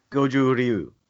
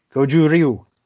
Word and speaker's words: Goju Ryu: Goju Ryu. – Goju Ryu.